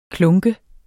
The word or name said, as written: klunke